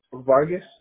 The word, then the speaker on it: Vargas: So my last name is Vargas, V-A-R-G-A-S.